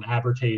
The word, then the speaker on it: Abertay: Abertay is correct.